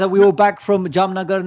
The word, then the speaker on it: Jamnagar: Jamnagar.